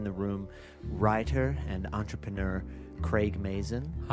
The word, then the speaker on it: Craig: Just a pronunciation note there. It's Craig.